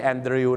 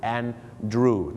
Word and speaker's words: Andrew: แปลว่ารู้นะฮะในอดีตนะครับ I knew her โอเคอันนี้นะครับจะมียกเว้นนิดนึงครับฝรั่งบางทีก็จะออกเสียงว่า I knew her นะครับบางคนจะออกเสียงสระอูนะครับ I knew her หรือว่า I knew her นั่นเองครับแต่อยากให้รู้จักนะครับว่าสระอูนะครับก็คือ Drew และแม้กระทั่งชื่อของคุณ Andrew นะครับไม่ใช่ Andrew นะฮะ Andrew นั่นเองครับ